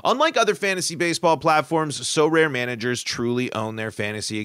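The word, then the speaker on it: Sorare: They're our new sponsor, and it's pronounced So Rare.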